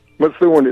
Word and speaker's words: Methuen: Methuen is the proper pronunciation.